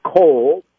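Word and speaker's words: kol (כל): And for those that may know Hebrew, it's easily pronounced. It's just kol, just like C-O-L or K-O-L.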